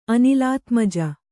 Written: ಅನಿಲಾತ್ಮಜ

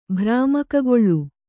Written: ಭ್ರಾಮಕಗೊಳ್ಳು

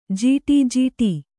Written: ಜಟಾಜೂಟ

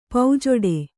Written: ಪೌಜೊಡೆ

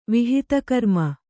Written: ವಿಹಿತಕರ್ಮ

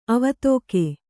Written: ಅವೇದಕ

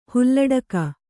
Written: ಹಾಲಡಕೆ

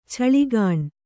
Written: ಛಳಿಗಾಣ್

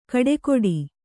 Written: ಕಡೆಕೊಡಿ

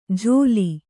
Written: ಜೋಲಿ

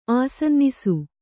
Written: ಆಸನ್ನಿಸು

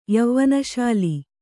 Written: ಯವ್ವನಶಾಲಿ

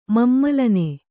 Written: ಮಮ್ಮಲನೆ

